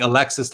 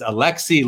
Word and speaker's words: Alexis: By the way, I was corrected by a French-speaking Instagram contact Facebook just today, I believe, sent me a message saying, in French, we don't pronounce the S. I've been saying Alexis Lafreniere. I'm now going to change it and say it's Alexis Lafreniere, and I didn't know that.